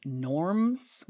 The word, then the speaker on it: Norns: I also saw that apparently it might be pronounced Norms with an M, but I hate that. I hate that so much. And it's spelled like N-O-R-N-S. So Norns, fight me, fight the man.